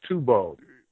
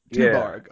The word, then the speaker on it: Tuborg: How would you say that? – Yeah, it was Tuborg. – Tuborg, yeah. Okay.